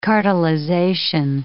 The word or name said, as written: cartelization